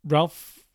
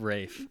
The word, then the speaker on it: Ralph: Ralph Fiennes, Ralph Fiennes, he you pronounce his name in a weird way, I think. – I've heard that, yeah. It's Rafe, apparently.